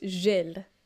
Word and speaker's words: Gilles: So Hunt returned the year after to win the second Japanese Grand Prix, but a collision between Gilles Villeneuve, did I say it right? No. No. Uh, no, uh, it's a soft G, so it's Gilles.